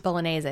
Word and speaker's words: bolognese: Bolognese, I think.